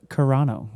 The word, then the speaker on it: Carano: By the way, I looked on Google for the pronunciation, and it is Carano.